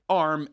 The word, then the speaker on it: ARM: So ARM, A-R-A, I guess, can I call it ARM, or do they pronounce themselves ARM? ARM, A-R-M, it's a chip design firm.